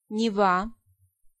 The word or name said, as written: Neva